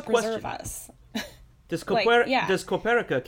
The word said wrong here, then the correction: Capoeira: count? I think I'm saying that wrong. Capoeira? The dancing fighting. Capoeira. Yeah, that's the one.